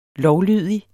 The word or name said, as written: lovlydig